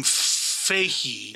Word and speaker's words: Feige: And why does he pronounce his name Feige? – Is it Booger? – No, hold on. So Kevin Feige, let me see.